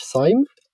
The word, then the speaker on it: Sejm: You were a member of the Polish Sejm, I hope I pronounced it correctly, the Polish Parliament.